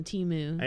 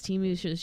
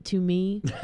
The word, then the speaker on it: Temu: She can't pronounce Temu. She calls it to me.